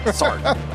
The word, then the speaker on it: Sartre: You don't pronounce R-E. – You don't. – Sartre. – I'll start there. – Sartre. – Sartre. I got to read some Sartre. – Rhymes with Jamie Tart.